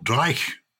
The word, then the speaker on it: dreich: The weather today is dismal or dreich Mm. – They'd say that in some parts of Scotland, don't they? am I pronouncing it correctly? – Yes, you are.